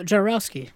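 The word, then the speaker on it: Jodorowsky: Maybe because there was a few delays on, you know, we talked about the set from, I always forget his name, Jabakowski, the big set. I'm saying his last name wrong. That is El Topo. – Oh, Jarowski.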